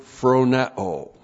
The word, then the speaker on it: phroneo: Phronema comes from the verb phroneo. Comes from the verb phroneo. P-H-R-O-N-E, long O.